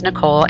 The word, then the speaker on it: Nicole: Realistically speaking, my name is Nicole, N-I-C-O-L-E, but some people spell it N-I-C-H-O-L-E.